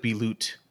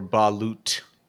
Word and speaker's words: balut: Do you pronounce the T? – Hey, I'm not a medical professional. Mm-hmm. It looks like I'm looking at the phonetic pronunciation, and it looks like balut or balut. The second pronunciation has the T on it.